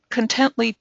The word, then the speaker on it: Contently: Before joining the Contently team, am I saying that right? I always say it that way, Contently. – Yeah, that's right. Absolutely.